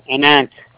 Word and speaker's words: Ananth: My name is Anant Yadav. – How do you spell that? – A-N-A-N-T-H. – A-N-A-N-T-H.